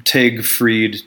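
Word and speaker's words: Tage Frid: They told me how to say his name right. It's, you know, I'm talking about Tag Fried, T-A-G-E Fried.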